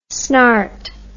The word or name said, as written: snart